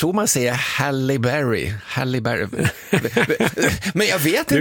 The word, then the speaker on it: Halle Berry: Halle Berry.